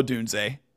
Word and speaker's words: Odunze: Romo Odunze,